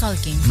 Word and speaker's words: Culkin: Culkin.